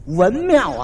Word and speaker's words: 文庙: 一指这块匾，文庙啊，给念成文朝了，念错了一个。